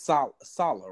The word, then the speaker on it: Saleh: His name is Saleh, right? That's how you pronounce the defensive coordinator for the 49ers. Yep.